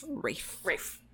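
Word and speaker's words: Ralph: Think of the pronunciation like R-A-I-F. – Rafe.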